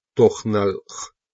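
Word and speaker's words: тональ: Я знал, что Таналь, произносится как Тохналх,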